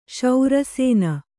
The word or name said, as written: ಶರಸೋನೆ